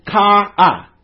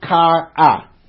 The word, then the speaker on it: chara: It's kara. And it's spelled C-H-A-R-A, but it's pronounced kara. Which means cheerfulness, calm, delight.